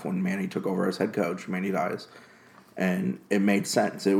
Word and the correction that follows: Diaz: How did you say his last name? – Diaz, whatever. Manny.